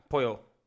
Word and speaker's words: pollo: Kentucky fría polla. Pollo, not polla.